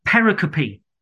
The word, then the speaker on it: pericope: Pericope, I want to introduce, and I have been corrected on the pronunciation of this slightly. So the English word we pronounce pericope is a division of a section of text. It comes from the Greek word perikope, I think, or something like that.